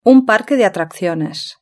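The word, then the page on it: un parque de atracciones: European Spanish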